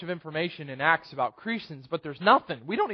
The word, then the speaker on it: Crescens: I believe it is Crescens is the right way to say his name. Crescens.